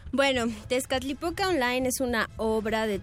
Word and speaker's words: Tezcatlipoca: sí, Ellos vienen a hablarnos acerca de la obra Tezcatlipoca Online. ¿Lo dije bien? Sí, sí, sí. Pronuncié bien el náhuatl y el inglés después.